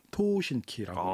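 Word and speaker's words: 토신키 (東方神起): 일본명으로는 토신키라고. – 아, 네, 일본에서. 발음이 그렇죠.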